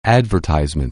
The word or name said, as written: advertise